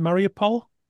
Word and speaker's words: Mariupol: Mariupol, yes.